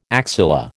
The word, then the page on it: axilla: American English